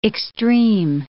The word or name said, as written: extreme